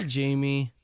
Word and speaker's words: Jaime: Is it Jamie? Are you Jamie or Jaime? – Yes, Jamie. – Hi, Jamie. – It is actually spelled like Jaime, though.